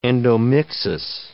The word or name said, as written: endomixis